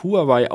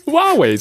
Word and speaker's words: Huawei: Äh, in Deutschland wird das häufig Huawei ausgesprochen, aber das ist eigentlich gar nicht richtig. Das heißt Huawei. Was für ein Ding? Huawei. Was für ein Ding? Huawei, so wird's ausgesprochen.